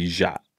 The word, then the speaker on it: Ya: My joke is that when you know when we came to the country through immigration, they couldn't pronounce my name. My my name in Hmong is actually Ja.